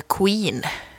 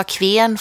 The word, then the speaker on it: Kween: Jag antar att man uttalade Queen. – Mm, det gör man. Kyr, jag, tänkte, jag sa kven först, för det är K-W-E-E-N. – Och det är eh, slangigt för Queen. – Ja.